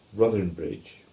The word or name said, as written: Ruthernbridge